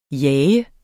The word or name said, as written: jager